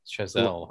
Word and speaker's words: Chazelle: This movie was directed by Damien Chazelle. Is that how you say it? – Yes, Chazelle. – Also directed. Like Gazelle.